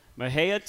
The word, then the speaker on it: Mexia: You are from a place called Mexia, Texas. It's spelled M-E-X-I-A. That is the right pronunciation, I think. – You nailed it.